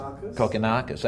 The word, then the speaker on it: Kokkinakis: Kokkinakis.